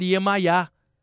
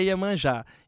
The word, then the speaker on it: Iemanjá: E como a Deva Premal é europeia, ela não consegue falar Iemanjá, ela fala Iemayá, né? A pronúncia é diferente, Iemayá, mas é Iemanjá.